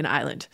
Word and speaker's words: island: All right, so let's talk about the mistake in island. Island goes back to Old English, and it was Ialand. And it was a compound. The second part is land. And the first part was a watery place, an island, Ialand. And by Middle English, the Renaissance, it's spelled I-L-A-N-D.